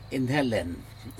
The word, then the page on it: inhelen: Aneityum